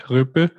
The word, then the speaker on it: ryyppy: It's not, to pronounce it a little bit hard. Ryppy, RYYPPY.